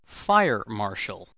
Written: fire marshall

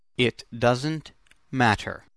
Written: it doesn't matter

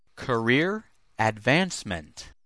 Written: career advancement

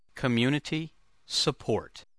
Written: community support

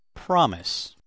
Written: promise

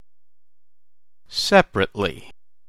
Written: seperately

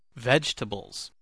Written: vegetables

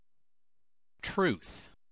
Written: truth

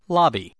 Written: lobby

